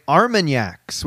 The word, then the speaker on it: Armagnac: How do you say it? – I suggested we all get Armagnacs, which is like a cognac, but a little different. – Yes, yes, yes. It's my winter Armagnac.